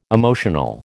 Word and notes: emotional: American English

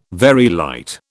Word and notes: Very light: British English

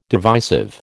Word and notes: divisive: American English

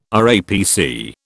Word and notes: RAPC: British English

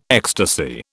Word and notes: ecstasy: British English

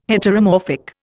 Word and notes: Heteromorphic: Indian

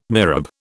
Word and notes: mihrab: British English

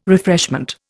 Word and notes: refreshment: Indian

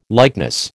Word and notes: likeness: American English

